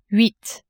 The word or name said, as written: huit